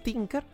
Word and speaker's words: Tinker: El The Last Tinker... No, sí, ¿no? Sí, iba a decir, se pronuncia Tinker, ¿no? Sí, porque Thinker sería pensador y no... No, le falta la H.